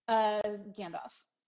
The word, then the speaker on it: Gandalf: Gandalf. – Okay. Gandalf. – Gandalf. – Gandalf. – The L is not silent.